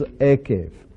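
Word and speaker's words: ekev (עקב): And that word in Hebrew is ekev.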